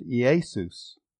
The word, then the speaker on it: Iesous: Now, in the Greek language, the word is in the Greek for Jesus is I-E-S-O-U-S, and it's pronounced Iesus.